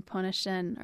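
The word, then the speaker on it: Pointe-au-Chien: Yeah, it's Île de Jean-Charles and Pointe-à-Chenne are the two places. And I think I still butcher some of the pronunciations in the area, but I think I have those two right because they're French.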